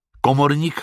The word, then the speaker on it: Komornik: Komorník Komorník. Jeho příjmení se píše Komornik, čte se komorník a profesí je to komorník, což je dobře, protože Packalovi aspoň nemůžou zapomenout, jak se jmenuje, nebo co dělá, nebo oboje.